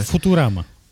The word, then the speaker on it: Futurama: Δεν ξέρω, θα με βάλει με το ζόρι να δω Φουτσουράμα τώρα. Φιουτσουράμα, πώ ε, λέγεται κανονικά. Πε να Φουτουράμα. – Φουτουράμα. – Φουτουράμα, γιατί okay. η προφορά του future είναι πολύ δύσκολο. Ε, Futurama. – Futurama, οκ. Ό,τι θε πάντω, από θέμα προφορά ε, το έχω.